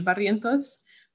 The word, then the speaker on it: Barrientos: But again, my name is Ariel Barrientos, but also in Spanish, it's pronunciated Ariel Barrientos.